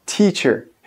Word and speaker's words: teacher: O som, a tendência do brasileiro é colocar t nesse primeiro t, mas o som é seco. T, teacher.